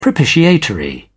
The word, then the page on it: propitiatory: British English